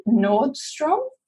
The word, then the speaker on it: Nordstrom: So, um, a good example of this is I don't know actually how to pronounce it, but Nordstrom.